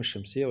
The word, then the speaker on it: الشمس: اللام القمرية هي اللام اللي موجودة في كلمة القمر وما يشبهها، سموها كده لأنها تنطق وتكتب، بخلاف اللام الشمسية مثل كلمة الشمس تنطق اللام، تكتب اللام ولا تنطق.